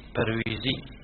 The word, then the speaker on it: برويزي: وهذا هي تنطق باللغة الأردية برويزي، هم يقولون أنفسهم برويزيين، لكن بالعربي تكتب والجماعة البرويزية، فيه برويزية في يا ساقطة بعد الواو.